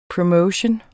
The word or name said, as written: promotion